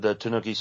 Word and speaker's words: Tanuki: Yeah, and that's the Tanuki suit is the one that lets you become a statue.